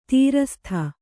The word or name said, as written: ತ್ರಸ್ತೆ